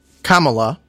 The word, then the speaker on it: Kamala: Kamala, I just tried to phonetically write that down, so I'm going to start working on it.